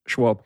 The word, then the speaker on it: Schwalbe: You're going to have to help me with the pronunciation of this. Schwalbe? – Schwalbe. – Yeah. Schwalbe, got it.